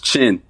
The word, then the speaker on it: Chinn: It's Chinn, right? Or is it Chinn? – It's Chinn. Chinn. – Chinn. The second N is silent. – Second. Silent, or you could just hold it in a little bit longer.